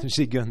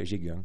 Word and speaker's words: Jégun: Et puis ensuite, il y a dans le Gers, Jégun, je ne sais pas si je prononce bien. – Jégun, dans le Jégun. – Gers. Avec l'accent, on dirait Jégun.